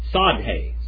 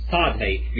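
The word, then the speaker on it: tsade: And then we have the sadhe. Sadhe. You start with a T. It's like a TS. Sadhe. Modern Hebrew will not pronounce the T at all. It'll just use it like a S.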